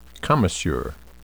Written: commisure

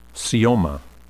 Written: caeoma

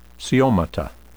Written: caeomata

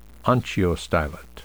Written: onchiostylet

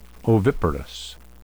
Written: oviparous

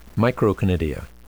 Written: microconidia

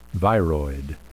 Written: viroid

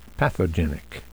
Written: pathogenic

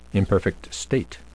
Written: imperfect state

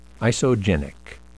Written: isogenic

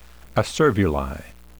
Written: acervuli

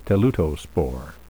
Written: teleutospore

